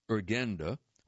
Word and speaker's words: Urgenda: She moved to London and joined Urgenda, which is spelled U-R-G-E-N-D-A,